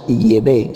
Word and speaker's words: Yahvé: en idioma hebreo arcaico es Yahvé, esa es la auténtica pronunciación.